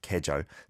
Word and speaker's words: 계절: With 계절, it is spelt with a 여이, the 계, but you don't overpronounce that like that. 계절. It's just like 계절, 사계절, 사계절. Four seasons.